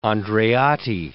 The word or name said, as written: Andreotti